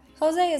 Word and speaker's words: José: Jose is not spelled how it sounds in English since it's a Spanish word.